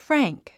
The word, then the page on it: frank: frǽŋk; フラァンク